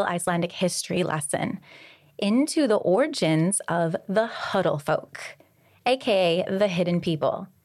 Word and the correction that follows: Huldufólk: Did I say that okay? – Yeah. – How would you say it? – Huldufolk. – Huldufolk. – Yeah. – That's better.